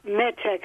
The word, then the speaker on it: Metanx: Hi, I wanted to ask your opinion on Metanex, M-E-T-A-N-X.